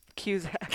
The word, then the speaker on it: Cusack: Kiernan Shipka, Jacob Batalon, and Joan Cusack. – Cusack. Freaking. How'd you screw that one up? – You know I can't say Cusack.